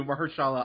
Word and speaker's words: Mahershala: But um, the brother who plays uh, the villain, uh, uh, Cornell Cottonmouth uh Stokes, that's um, I am going to butcher this name. Uh, Maher Mahershala. Oh, two for two. Mahershala Ali.